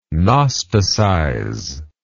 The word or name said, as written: gnosticizer